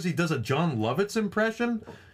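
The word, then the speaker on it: Jon Lovitz: But it's one of those times where JR tries to make a reference to pop culture and mispronounces the name, and he calls him John Lovett.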